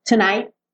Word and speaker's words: tonight: Tonight. O T quase não aparece, né? Só lembra de não deixar aberto assim, tonight. Bota a linguinha lá no céu da boca,